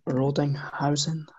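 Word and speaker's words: Rödinghausen: Next up, we've got Lars Lacoste from SV Rodinghausen. I believe that's how you say it. – Yeah.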